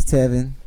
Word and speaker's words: Tevin: Hi, my name is Tevin. – Rhymes with 7.